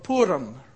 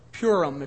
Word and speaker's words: Purim: Purim, or Purim, if you want to pronounce it as North Americans here may pronounce it.